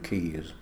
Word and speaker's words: Caius: His usual spelling was K-E-Y-S, but when it came to Latin documents, it was convenient to use the Latinised version, C-A-I-U-S, but we still keep the pronunciation of Keys.